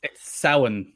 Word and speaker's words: Samhain: Is it Samhain? – It's Samhain. – Oh, I was way off then.